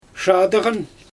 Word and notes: ʃaadɯɣan: Xyzyl

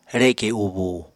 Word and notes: reke ubúu: Apatani

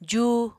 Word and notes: jú: apatani

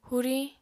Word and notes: huriñ: apatani

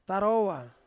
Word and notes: tarowa: Ansus

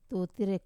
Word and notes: tutira: Wamesa

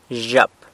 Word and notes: zhyap: girl

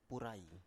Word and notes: purai: Biak

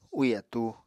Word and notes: úì atúu: Apatani

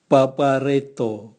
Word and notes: bwabwareato: Raga

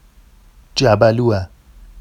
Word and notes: جَبَلووَ: jabalua; Vafsi

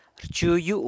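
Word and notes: rchu yu: San Lucas Quiaviní Zapotec

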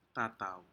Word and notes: ta tau: Aneityum

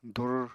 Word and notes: dur: apatani

